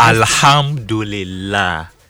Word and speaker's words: Alhamdulillah: Alhamdulillah. – Alhamdulillah. – You pronounce it slowly. – Slowly. – Now just say it like you're talking.